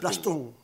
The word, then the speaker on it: Plastún: ¿Plastún o Plastún? – Plastún. – Plastún. – Acento en la N.